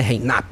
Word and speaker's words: Reinup: Escreve-se Reinup. Mas a pronúncia é mais inglesa, né? Reinup.